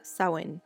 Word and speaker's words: Samhain: Lo busqué en varias partes y claro, en todas partes se pronuncia Sawen.